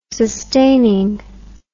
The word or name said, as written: sustaining